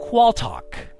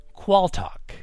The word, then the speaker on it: Qualtagh: Alrighty, the word for round 2 is Qualtalk. Qualtalk. That is spelled Q-U-A-L-T-A-G-H.